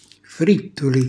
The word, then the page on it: frittuli: Calabrese